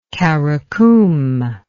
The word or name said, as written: qaraqum